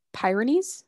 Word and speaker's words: Pyrenees: Is it Pyrenees? – And we're gonna go with that. I'm pretty sure that sounds right. Great Pyrenees.